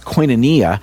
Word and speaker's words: koinonia: The Greek word that's translated fellowship is pronounced koinonia.